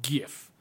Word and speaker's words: GIF: And this title line is GIF has a hard G. – Ooh. – So that's GIF.